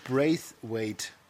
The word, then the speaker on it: Braithwaite: So, Martin Braithwaite, also B-R-A-I-T-H, also Braith, ja. Und dann W-A-I-T-E, Wait.